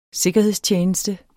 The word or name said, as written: sikkerhedstjeneste